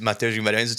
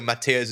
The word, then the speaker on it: Mateus Guimarães: My name, as I said, is Mateus Guimarães. It's a little bit hard to pronounce in English, so Mateus is fine for all of my English-speaking friends.